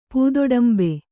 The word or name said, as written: ಪೂದೊಡಂಬೆ